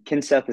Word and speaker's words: Kinseth: And Kinseth is spelled like kind Seth. And it's Ryan with an R, R-Y-A-N.